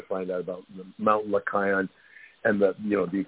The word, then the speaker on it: Lycaon: Lycaon, Lycos is wolf, so it's Mount Lycaon.